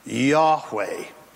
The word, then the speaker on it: Yahweh: Notice how Lord in your translations is capital L, capital O-R-N-D. Do you notice that? There would be four Hebrew characters here. The English transliteration would be Y-H-W-H. We would insert vowels for the sake and convenience of our tongue, and we would pronounce this word in Hebrew, Yahweh, the eternal existing one.